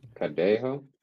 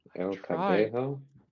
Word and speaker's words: Cadejo: El Cadillo. – El Cadillo. – Cadejo. – I'm sorry. – El Cadejo.